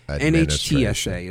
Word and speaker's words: NHTSA: NHSTA, I think, is the agency. I think they require... – National Highway Traffic Safety Administration. So NHTSA, sorry. NHTSA is how you pronounce it.